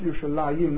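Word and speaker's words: Yerushalayim (ירושלים): If you look at the Tzurat Tanakh, Yerushalayim, except in six places, is always written without a vav, without the Yud, Yerushalayim, but we pronounce Yerushalayim, that's a clear nixiv, that's missing.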